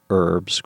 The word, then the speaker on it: herbs: Do you say herbs or herbs? – I've never said herbs with the pronounced H, never once. And it falls funny on mine ears, okay. – My American ears.